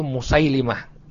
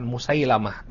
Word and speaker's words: Musailimah: Ya, yang benar membacanya itu Musailimah, bukan Musailamah, tapi Musailimah. Ya, tasghir dari Maslamah jadi Musailimah.